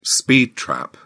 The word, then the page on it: speed trap: British English